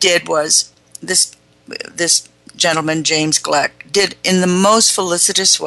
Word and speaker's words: Gleick: Chaos, Making a New Science, national bestseller, James Gleick. It's spelled G-L-E-I-C-K, Gleick.